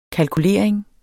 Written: kalkulering

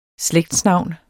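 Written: SLÆGTSNAVNET

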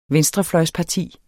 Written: venstrefløjsparti

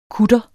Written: KUTTERNES

